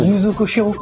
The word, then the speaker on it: Yuzo Koshiro: Yuzo Koshiro. Exact. Est-ce que je l'ai bien dit? Je l'ai bien prononcé. – Tu l'as très bien prononcé.